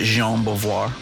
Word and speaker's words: Jean Beauvoir: I'm glad he pronounces it Jean Beauvoir. – For a while, I didn't know like a proper who Frenchman. – That dude was. Yeah, it's like, who's Jean Bouvier?